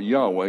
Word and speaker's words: Yahweh: Some people pronounce it Yahweh, and it probably is better pronounced that way because the H there has a sound, kind of like the Russian sound and like the Hebrew sound, but I always call it Yahweh.